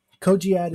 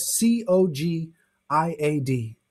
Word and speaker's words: Cogiad: Cogiad is spelled C O G I A D if I had animation, I would put it on the screen here.